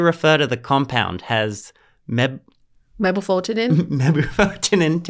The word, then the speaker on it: mebufotenin: Mebufotenin. – Okay, how do you pronounce that? I've been trying to figure Mebufotenin. – It out. Mebufotenin.